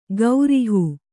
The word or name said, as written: ಗೌರಿಹೂ